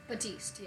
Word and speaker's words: Baptiste: Baptiste. – Nope, not a Baptiste. Little. I'm gonna call him Baptiste. – Why can't you hear a Baptiste going to the bathroom? Because the pee is silent.